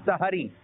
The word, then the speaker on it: سحری: اچھا اس کی صحیح جو ادائیگی ہے نا صحیح تلفظ ہے سحری, سین پر بھی زبر ہا پر بھی زبر, ٹھیک ہے, یہ بولا جائے سحری کا وقت ہو گیا ہے.